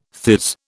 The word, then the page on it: theirs: British English